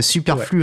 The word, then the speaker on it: Superflu: Bon, la première question, et je pense que je ne suis pas le seul à me la poser, c'est comment est-ce que ça se prononce? Est-ce qu'il faut probablement prendre son bon gros accent français, Superflu ouais. Returns? – C'est exactement ça.